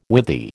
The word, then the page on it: withy: American English